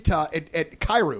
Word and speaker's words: Kyrou: Well, I mean, even when you look at Kyrou, am I pronouncing his last name right? – Yep.